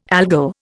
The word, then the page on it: Algol: Indian